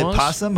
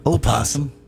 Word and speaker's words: opossum: Is it possum or opossum? – I thought it was just possum. – I think the correct pronunciation is actually opossum.